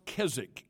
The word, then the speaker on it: Keswick: It's pronounced Keswick. It's a British word. So when you read the word, don't pronounce it like it looks.